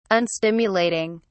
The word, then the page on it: unstimulating: British English